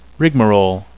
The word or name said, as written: rigmarole